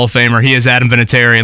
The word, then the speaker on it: Vinatieri: So, um, yeah, it's, we, we say Vinatieri, but I've heard a lot of people pronounce it with more enunciation. So if you want to call me Adam Vinatieri, knock yourself out.